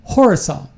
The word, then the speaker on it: Khorasan: And ISIS K, it's pronounced Horasan. That's what the K is, stands for. It's spelled K H O R A S A N. Khorasan province. Mm-hmm. But you really, the way you pronounce it is Horasan. The K is silent.